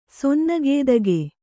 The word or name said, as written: ಸೊನ್ನಗೇದಗೆ